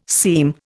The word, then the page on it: seam: Indian